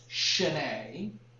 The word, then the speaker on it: Sine: The name of that bar, it looks like in English it's sign, S-I-N-E, but in Irish that's pronounced Shine.